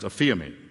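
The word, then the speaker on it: aphiemi (ἀφίημι): It's pronounced aphiemi.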